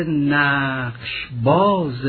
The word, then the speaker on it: نقش باز: حتما دوستان و دانش آموزان عزیز نقش را در مصرع نخست اندکی مصوب تلفظ بفرماید و الا وزن فرو می افتد. نقش باز، چون چند مصوب در پی هم اگر به اصطلاح ما کشیده نخوانیم وزن لطمه می خورد.